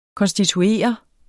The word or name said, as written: konstituere